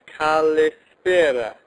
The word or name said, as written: kalispera